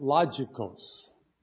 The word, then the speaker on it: logikos: In my English, I would pronounce it logikos. Okay, we say logos. Logikos is a derivative of that word logos.